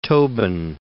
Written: Tobin

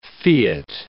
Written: fiat

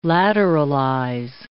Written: lateralize

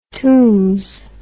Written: tombs

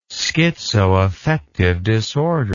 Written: schizoaffective disorder

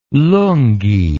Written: lungyi

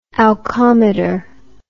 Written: alcometer

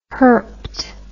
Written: herped

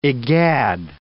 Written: egad

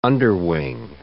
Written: underwing

